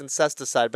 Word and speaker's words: Incesticide: I've always pronounced it as Insecticide. – I always thought it was Insecticide, but it's Incesticide. But yeah, that's like a lot of their kind of like B-side crazier shit.